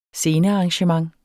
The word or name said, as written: scenearrangement